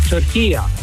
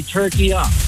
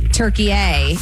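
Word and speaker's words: Türkiye: At the end is a short A. It's not a long A. In the Arabic language, it's called turkiya. So you could say turkiya. – You and I are making it a hard A. Turkey A, and it's turkiya.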